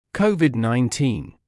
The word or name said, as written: COVID 19